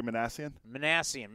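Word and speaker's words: Minasian: How do you say his name? The Angels GM? – Perry Manassian.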